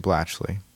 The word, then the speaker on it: Blachly: The fact that I co-wrote this song with Clarence Dan Blachly.